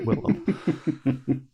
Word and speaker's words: Willem: It was William, and everyone and people always call him William Dafoe. He's like, no, it's Willem, but it is William, but it's Willem. I love it. – It's the L is silent.